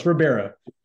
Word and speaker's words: Ribeiro: Is it Ribeiro? – It's super close. Ribeiro.